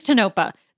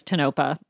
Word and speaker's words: Tonopah: I think it's Tonopah. The only reason why I know how to say it, even though I keep messing up, is because of the Clownvis video, I will admit, because he says Tonopah multiple times.